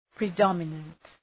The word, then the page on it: predominant: prı’dɒmənənt